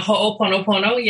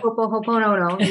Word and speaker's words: Ho'oponopono: And of course, I know all about this word that I can't pronounce, Ho'oponopono. – Ho'oponopono. Yep. Yeah. – Ho'oponopono. You said it perfectly.